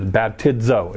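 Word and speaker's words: baptizo (βαπτίζω): Now, if you translate the word baptizo, which is the Greek word, it is baptizo. That's how you say it.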